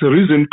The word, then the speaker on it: Reason: That's Reason P.